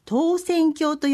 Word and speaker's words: 投扇興: はい、投げる扇に興じると書いて投扇興と読む投扇興体験会についてご紹介します。